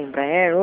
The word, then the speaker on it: Embraer: Então, a gente está em parceria com a Embraer hoje. Ah, falei certo agora.